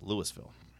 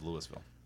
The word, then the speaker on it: Louisville: Louisville as in not Louisville, Kentucky, but down there around, down the Alcoa. – That's pronounced as Louisville. – Louisville? – One in Kentucky's Louisville. This one's Louisville.